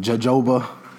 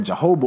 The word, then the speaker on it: jojoba: Some people probably been pronouncing it jojoba. It's not jojoba oil. It's that first J is a J. The second one is pronounced like a H, as in Rojo Show.